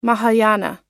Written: mahayana

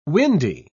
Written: windy

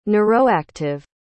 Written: neuroactive

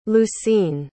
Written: lucine

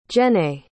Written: jennae

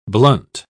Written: blunt

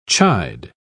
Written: chide

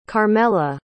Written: carmela

